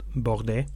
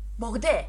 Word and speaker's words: Bordet: So Jules Bordet and his whooping cough vaccines. There you go. – Yeah, I think his name is pronounced Bordet. – Bordet without the T.